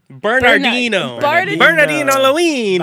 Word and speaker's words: Bernardino Luini: Bernardino Luini.